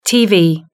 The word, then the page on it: tv: British English